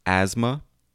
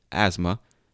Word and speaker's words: asthma: Okay, for example, you have the words asthma, A-S-M-A, asthma. So, but now, today, we spell it A-S-T-H-M-A. Pronounced the same exact way, asthma. When it looks like asthma. But no, it's asthma. Another one, diarrhea, was spelled D-I-A-R-I-A. But today, today, we spell it D-I-A-R-R-H-E-A.